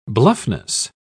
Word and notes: bluffness: British English

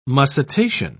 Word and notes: mussitation: British English

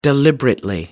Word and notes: deliberately: American English

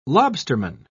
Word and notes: lobsterman: British English

